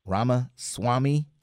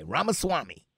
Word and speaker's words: Ramaswamy: But he tells Vivek Ramaswamy, and he kind of likes Ramaswamy. I think that's how you pronounce his name. R-A-M-A. Ramaswamy, S-W-A-M-Y, Ramaswamy.